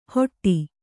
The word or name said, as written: ಹೊಟ್ಟಿ